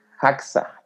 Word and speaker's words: JAXA: Y Y cuenta que ese se, te digo que se hizo en colaboración con JAXA, JAXA se pronuncia en inglés y es la Estación Espacial eh, este, la Agencia Espacial Japonesa, perdón.